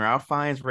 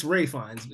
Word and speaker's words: Fiennes: I think Ralph Fiennes, or how do you say his name? Ralph Fiennes, Ray Fiennes. – It's Ray Fiennes. Ray Fiennes.